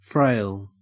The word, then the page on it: frail: Australian English